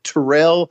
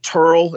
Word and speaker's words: Terrel: And I think the town was pronounced or it was spelt T-E-R-R-E-L, and we thought it was Terrell, and they pronounced it Turl,